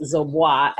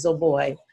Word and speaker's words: Zoboi: A lot of people think that it's Zoboi, as in Dubois, but it's my husband's Liberian name, and it's pronounced Zoboi.